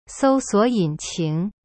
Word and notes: 搜索引擎: sou suo yin qing

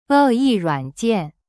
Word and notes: 恶意软件: e yi ruan jian

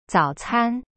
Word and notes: 早餐: zao-can

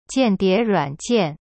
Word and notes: 间谍软件: jian die ruan jian

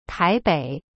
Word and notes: 台北: tai-bei

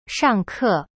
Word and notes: 上课: shang ke

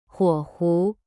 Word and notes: 火狐: huo hu

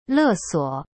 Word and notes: 勒索: le suo

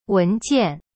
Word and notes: 文件: wen jian

